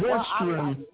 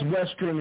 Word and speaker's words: WSTRN: This is Western. The name of the group is Western, and you spell it W-S-T-R-N, Western.